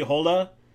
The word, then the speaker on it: Hole: The main character is played by Fassbender, is Harry Hole.